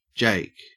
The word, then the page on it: jake: Australian English